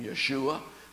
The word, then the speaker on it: Yeshua: Yeshua, I like to call him Yeshua. That's the Hebrew pronunciation of Jesus.